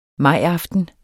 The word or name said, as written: majaften